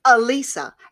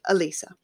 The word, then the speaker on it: Elissa: It's Mona Lisa without the moan. So think it through and go, Elissa, and there you go. That's how you say my name, Elissa.